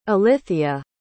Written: ilithya

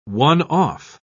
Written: one-off

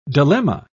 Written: dilemma

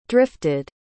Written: drifted

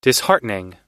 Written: disheartening

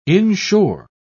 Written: inshore

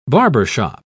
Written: barbershop

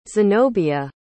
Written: zenobia

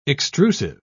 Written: extrusive

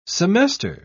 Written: semester

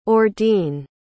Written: ordene

